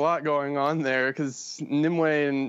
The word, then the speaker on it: Nimue: is it Nimue, am I saying it right? – Yeah, she's actually, you know what, I was gonna give Arthuriana background, you take this one.